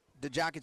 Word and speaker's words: Djock: Yeah, and gain of 3 for DeJock. It's spelled DJOCK, and he's got up to 17 yards rushing now, 2nd and 7 from the 39.